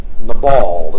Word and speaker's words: Nabal: I suppose there's a number, but the man named Nabal is how you'd really say his name, 1 Samuel 25.